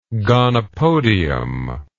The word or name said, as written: gonopodium